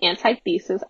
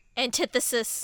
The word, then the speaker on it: antithesis: I'm gonna say antithesis. I know that's not how it's said. – Antithesis.